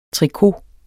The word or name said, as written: trikot